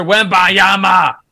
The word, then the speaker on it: Wembanyama: So, yesterday, the San Antonio Spurs announced that number one pick, Victor Wembanyama, will sit out the remainder of Summer League.